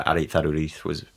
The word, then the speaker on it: Aritz Aduriz: Aritz Aduriz. – Yeah, I think that's, it's a Basque name, so yeah. It's pronounced slightly differently. But yeah, Aritz Aduriz would be how I'd say that.